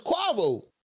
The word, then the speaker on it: Quavo: That's how you pronounce his name. I never knew it was Quavo.